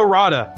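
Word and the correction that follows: Radja: You butchered his name. It's Dino Raja, but yes, that's correct.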